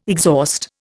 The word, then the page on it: exhaust: Indian